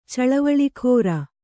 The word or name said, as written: ಚಳವಳಿಗಾರ